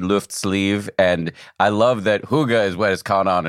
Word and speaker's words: hygge: And hygge, am I pronouncing that right? – Well, that's Danish, so that's a little out of my expertise, since I'm Swedish, okay. But I would pronounce it hygge.